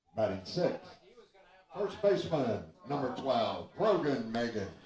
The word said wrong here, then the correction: Meehan: Brogan Meehan, I think, is actually how you pronounce it.